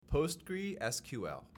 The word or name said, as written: PostgreSQL